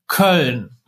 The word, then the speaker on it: Köln: Ja, und du kommst ja jetzt auch nicht auf die Idee, einem Ami zu sagen, hey, sorry, it is pronounced Köln.